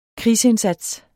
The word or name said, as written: krigsindsats